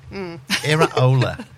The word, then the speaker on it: Iraola: And this is how you pronounce it. – Iraola. – What? – Iraola. That's a second name.